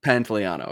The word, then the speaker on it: Pantoliano: How do you pronounce Joe's last name? – Pantaleano. – Okay. Sure. Between Robert Wool and Joe Pantoliano and Kathleen York, obviously, is Coralie.